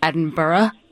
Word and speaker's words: Edinburgh: And he goes, can you please tell John that my dad is from Scotland, and it's pronounced Edinburgh? – Edinburgh. – Burra, without an H at the end.